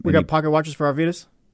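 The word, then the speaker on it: Arvydas: A-R-V-Y? – That's it. – Arvidas. – You got it. – Well, D-U-S? – No.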